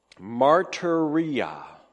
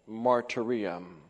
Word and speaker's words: martyria: Well, let me pronounce for you the Greek term, martyria. Sound familiar? Our term martyr derives from the Greek term for testimony or witness in verse 19, martyria.